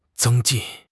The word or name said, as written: zeng1 jin4